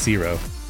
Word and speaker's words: CSIRO: The CSIRO, maybe it's pronounced Cesaro, I don't know. – I think it's Zero. – Zero? Okay, yeah, that makes sense.